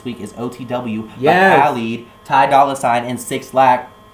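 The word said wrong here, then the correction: 6LACK: Black? It's pronounced black.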